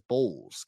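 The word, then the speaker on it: Bolles: That kid, Bowles, pronounced as Bowles.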